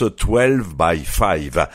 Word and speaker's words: 12 X 5: 12 x 5 se prononce 12 by 5.